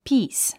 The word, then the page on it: peace: píːs; ピース